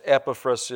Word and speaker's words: Epaphras: Colossians chapter 4 and verse number 12, it says, I pronounce this Epaphras, it's pronounced different ways, but I just pronounce it the way I want to.